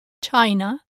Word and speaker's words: China: In my experience as a Spanish teacher, the most common error is to say it like in the English word China. In Spanish, the word China is China. China. With an E. E. China. China.